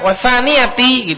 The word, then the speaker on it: wathaniyati: Wathaniyah, wathaniyati, gitu bacanya.